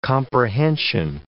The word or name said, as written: comprehension